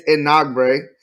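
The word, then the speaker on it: Enagbare: Enagbari from South Carolina. – So it's Enagbre. – See, I got it wrong.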